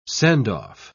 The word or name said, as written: send-off